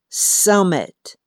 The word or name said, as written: summit